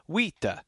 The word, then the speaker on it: vita: It's the E is not silent, but it's not like vita, you know, it's not like two equal syllables. Vita. You just give it a little schwa, just a bit of an at the end. Vita. Stress heavily on the first syllable, but then it just a little bounce on the second syllable, and that's how the terminal E goes.